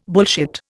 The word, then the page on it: bullshit: Indian